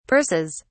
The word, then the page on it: purses: British English